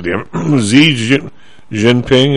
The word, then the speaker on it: Xi Jinping: Do you have any idea that these guys, how do you pronounce it? Xi Jinping, is that how you pronounce it? – Xi Jinping, yeah.